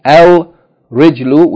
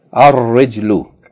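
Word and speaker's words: ar-rijlu (الرِّجْلُ): Instead of the lam being pronounced, the ra is pronounced double. Instead of saying al-rijlu, we say ar-rijlu.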